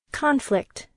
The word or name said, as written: conflict